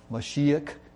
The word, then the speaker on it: Mashiach: And Ha is the, Mashiach, little k sound at the end.